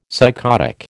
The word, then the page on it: psychotic: American English